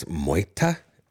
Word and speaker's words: Moita: We're going to play, I don't know, I think I've been pronouncing the name of this band wrong for a long time. Apparently it's Moita.